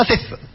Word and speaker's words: thistle: It has a silent letter in there somewhere. I don't know how to spell it. You've never seen this word, have you? No. No. – How do you spell thistle? T H I S T L. It's like whistle.